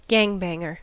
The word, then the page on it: gangbanger: American English